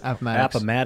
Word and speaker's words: Appomattox: Appomattox.